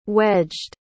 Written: wedged

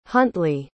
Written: huntly

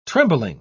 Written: trembling

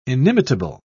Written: inimitable

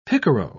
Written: picaro

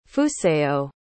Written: fusayo

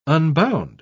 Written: unbound

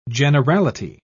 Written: generality

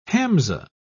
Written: hamza

